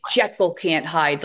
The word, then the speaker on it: Jekyll Can't Hyde: I just want to make sure for the listeners that I pronounced It's Jekyll Can't Hyde, like Dr. Jekyll, Mr. Hyde. Yes.